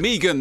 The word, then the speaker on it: Megan: So if a plant-based seed has been planted and you want to seed more, look no further than Megan that rhymes with vegan.